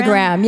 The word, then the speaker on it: Enneagram: Enneagram? – Enneagram, yeah.